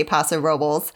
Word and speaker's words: Paso Robles: It's really hard for me to not say it in the to say it the way the locals say it. They say Paso Robles in California, which is Central Coast.